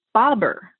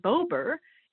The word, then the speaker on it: Bober: So Bobber would have double B's. But when a syllable is open, like the bow in Bober, it ends with a long vowel.